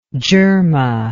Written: dyerma

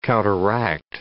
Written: counteract

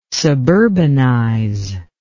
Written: suburbanize

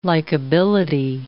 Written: likability

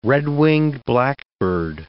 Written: red-winged blackbird

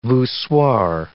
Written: voussoir

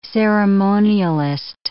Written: ceremonialist

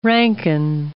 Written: Rankine

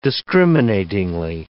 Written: discriminatingly

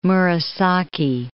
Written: Murasaki

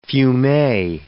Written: fumet

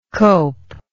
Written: copelessness

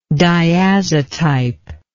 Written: diazotype

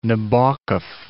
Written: Nabokov

